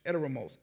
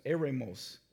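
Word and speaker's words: eremos (ἔρημος): Now, the word wilderness, in its original language, Greek, is pronounced eremos, eremos, and translates in meaning many different ways, but it's all the same.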